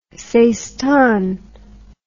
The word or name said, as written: seistan